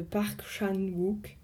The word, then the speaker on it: Park Chan-wook: En fait, ouais, si j'étais en fait un objet qui m'a particulièrement marqué, c'est les boules de geisha du film Mademoiselle de Park Chan-wook, si ça se prononce comme ça. – Oui, c'est comme ça.